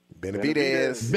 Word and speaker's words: Benavidez: Benavidez.